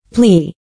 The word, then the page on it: plea: plíː; プリー